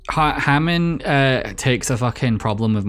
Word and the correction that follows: Haman: Haman instead of Hammond. I keep thinking you're saying like Richard Hammond, and I'm like, what? It's Haman. I think it's Haman in the thing, because I just remember her saying it like that.